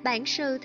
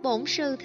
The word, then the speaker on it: Bổn Sư: Âm chuẩn là Bản Sư Thích Ca Mâu Ni, nhưng miền Nam đọc là Bổn Sư Thích Ca Mâu Ni. Đọc chữ bản thấy rất xa lạ. Người miền Bắc không thích đọc Bổn Sư.